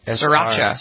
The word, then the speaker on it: Sriracha: Merriam-Webster, very excited this week, added, how do I pronounce this? Sriracha? – Sriracha.